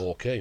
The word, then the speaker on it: Moca: One of the famous things is, it's a place called, I think it's pronounced Moca. – Yes. – Moca in Puerto Rico.